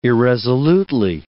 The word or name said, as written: irresolutely